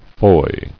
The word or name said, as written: Foy